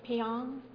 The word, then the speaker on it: Pyong: So the first person that I'd like to introduce is Vatana, and if I say the last name, Pyong? – Yeah. – Yeah, I said it right, thank you.